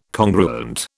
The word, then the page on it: congruent: British English